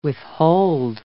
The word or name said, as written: withhold